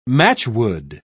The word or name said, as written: matchwood